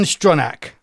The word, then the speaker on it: Stronach: The way you've typed it into the notes, it's Strunch, but it might be Strunch. It might well be Stronach, mightn't it? Or whatever the correct pronunciation is. It is. Sorry, that's a mistyping of me in the notes. It's Ian Stronach.